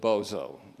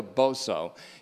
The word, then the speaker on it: Boso: B-O-S-O, and it's pronounced Boso, not to be confused with Bozo, the clown.